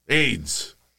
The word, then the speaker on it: AYDS: es AYDS, o sea, A-Y-D-S eh, pero se pronuncia igual, AIDS.